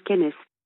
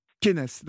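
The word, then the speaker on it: Kennes: Alors, ça se prononce Kenes. – Kenes, d'accord.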